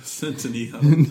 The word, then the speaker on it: Centineo: Centineo.